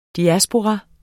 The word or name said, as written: diaspora